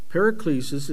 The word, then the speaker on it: paraklesis: Paraklesis, it's spelled P-A-R-A-K-L-E-S-I-S, paraklesis.